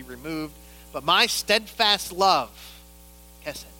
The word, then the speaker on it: hesed: There's a word in Hebrew called hesed. I know I didn't say that right, but it's hesed.